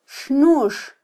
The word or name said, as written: xnux